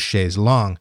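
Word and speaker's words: chaise longue: Now, as a lover of words, I truly appreciate Wet Leg for teaching me the actual pronunciation of Chaise Long, though they're not sticklers about it.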